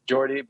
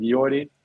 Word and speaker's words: Jordi: Well, we usually say, well, if you get to England, they say like Jordi, but it's, yeah, we just pronounced it Jordi.